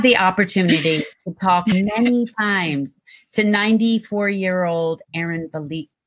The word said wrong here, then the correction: Bielski: Aaron Bielski.